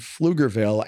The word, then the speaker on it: Pflugerville: That's the place that starts with a P. – Yes. – But it is not, you don't pronounce the P. – Yeah. – Pflugerville. – Yeah, I call it Pflugerville.